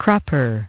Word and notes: crupper: British English

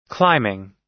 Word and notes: climbing: American English

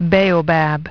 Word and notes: baobab: British English

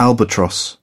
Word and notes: albatross: British English

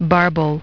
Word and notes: barbel: British English

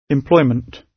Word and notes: employment: British English

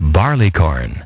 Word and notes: barleycorn: British English

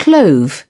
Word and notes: clove: British English

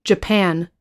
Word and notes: japan: American English

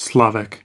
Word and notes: slavic: American English

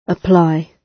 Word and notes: apply: British English

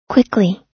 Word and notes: quickly: American English

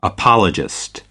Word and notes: apologist: American English

